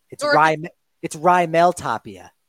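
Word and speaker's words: Raimel Tapia: You're like, oh, no, it's Rymel Tapia. You pronounced it wrong.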